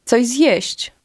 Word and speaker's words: coś zjeść: Coś zjeść. Coś zjeść. – Did you hear how the two words run together and almost sound like one? Listen and repeat to practice your pronunciation.